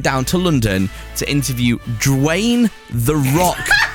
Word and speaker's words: Dwayne: That's how you say his name. – You said Drain. – No, I didn't. I said Dwayne. Did I say Drain? – He started off Drew and then you went Wayne, like Dwayne.